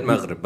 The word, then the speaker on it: Maghreb: He meant Maghreb, by the way.